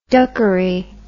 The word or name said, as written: duckery